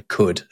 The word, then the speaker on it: could: They're also the reason why there's a silent L in could as well,